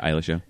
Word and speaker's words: Eilish: Yeah, Eilish.